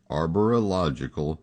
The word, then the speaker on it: Arborological: Did you hear how I pronounce it? Arborological Services. If you're into phonics, that'll help you spell it as well.